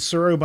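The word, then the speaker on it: Tsuro: Sykoro, S-A-I-K, O-R-O, Sycoro. Okay. And Suro, by the way, in case anyone wants to look it up and has not seen our website, it's T-S-U-R-O. The T is silent.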